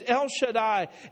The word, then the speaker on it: El Shaddai: And to know every one of those names, El Shaddai is pronounced just like I did right now, but the pronunciation of it is not as important as what it is.